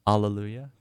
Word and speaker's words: Alleluia: I believe it's pronounced Alleluia, kind of like Hallelujah, off their album Live at the Lighthouse, 1966.